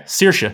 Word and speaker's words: Saoirse: Is that really how it's said? Saoirse? – Yeah, Saoirse. Huh.